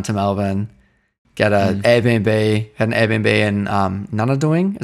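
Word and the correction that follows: Nunawading: How do you say not a doing, not a wedding, not a wedding?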